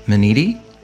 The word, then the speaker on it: Minniti: That's pretty good. It was actually very accurate. – I could have been better. – No. Well, yeah, but only with that Italian accent, like Minniti.